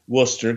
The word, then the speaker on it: Worcester: and it was entering Wooster, and it's spelled W-O-O-O-O-S-T-E-R, which is not the correct way that they spell Wooster, because they spell it up here like it says Worcester, but it's pronounced Wooster.